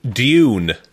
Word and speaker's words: Dune: am I pronouncing that correctly? I don't want to embarrass myself. – Dune. It's an effort for us with our accents, man.